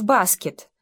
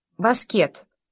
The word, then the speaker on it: баскет: Заторчав в жизнерадостном веселье, ребята выходили на площадку и предлагали двум дедам сразиться в баскет, то есть с ударением на последнем слоге. Баскет.